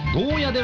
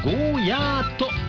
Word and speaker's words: ゴーヤー: こちらはツイッターのガジャッキさん、沖縄からラジコプレミアムで聞いていますが、ゴーヤではなくゴーヤーと言ってくださると幸いです。